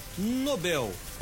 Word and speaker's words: Nobel: A primeira, a palavra sobre que acompanha o prêmio, na verdade, se pronuncia Nobel, com a sílaba tônica bel, chamada oxítona. A palavra Nobel para oxítona, por exemplo, no Prêmio Nobel, por mais que seja utilizada, é a forma errada, incorreta.